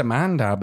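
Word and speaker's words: Amandla: It's just Amanda, but then it's Amanda. I always want to get rid of that L.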